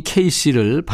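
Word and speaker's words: KC: 성 이 케이씨를 발음대로 알파벳 KC로 표기하고요.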